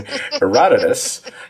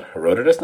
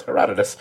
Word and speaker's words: Herodotus: Because Frenchie drops a quote from the ancient Greek historian Herodotus. And yes, I'm pretty sure that is how it's pronounced. I actually looked it up to make sure it's not Herodotus. No, it's Herodotus.